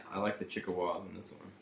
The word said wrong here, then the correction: Chippewas: He keeps calling them the Chickawas. They won't say it correctly. – What is a Chickawa? – The Chippewas. – It's Chippewas.